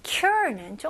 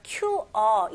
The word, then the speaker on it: cure: R는 힘을 주시고 R 발음이 혀가 이제 당기는 느낌이 나야 돼요. 그래서 QR 이게 아니고요. Cure 혀가 이제 꼬부린 느낌 나야 돼요.